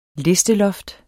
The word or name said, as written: LISTELOFTER